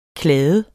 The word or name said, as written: kladde